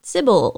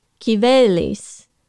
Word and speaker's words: Cybele: There is a goddess whose name is spelled C Y B E L E, or sometimes is begun with a K in English, we would probably pronounce that Sibyl or Sibylle. But in modern Greek, it's more like Kivelis.